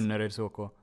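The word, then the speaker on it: Önnereds: Sag du nochmal vielleicht, wie man das... – Önnerets HK. – Ja, sehr schön.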